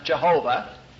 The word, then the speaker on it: Jehovah: And this, back in those days, tended to be pronounced Jehovah, because in the European languages, J's are pronounced likewise, like Yam and Yelli.